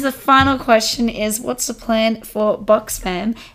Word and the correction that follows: Box Fam: Anyway, so what's the plan, Box Fam, and how do you want it to progress in the future?